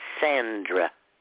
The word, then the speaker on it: Schisandra: S-C-H-I-S-A-N-D-R-A. Shisandra.